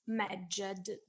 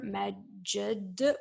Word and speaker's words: Medjed: So, well, now we too know the name of our creature, who we conventionally pronounce Medjed, because the Egyptian language only recorded consonants and not vowels. And so to be able to pronounce these three consonants together, Medjed, we must add something in between, and we conventionally add a vowel This, I want to read this phrase again.